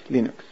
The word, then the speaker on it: Linux: Hello, this is Linus Torvalds, and I pronounce Linux as Linux.